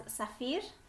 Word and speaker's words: Safir: Yeah, it is spelled S-A-F-I-R, and I would usually spell it as Safir. But sometimes the emphasis, depending on where I'm living, people can emphasize different parts of it. So sometimes they will call me Safir, sometimes they call me Safir, sometimes they call me Sophia, sometimes they call me something completely unrelated.